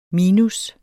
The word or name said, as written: minus